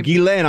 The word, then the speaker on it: Ghislaine: Ghislaine.